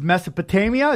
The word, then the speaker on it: Mesopotamia: The last point I want to bring up about last episode, and it really opened my eyes, was Mesopotamia.